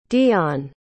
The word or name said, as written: deon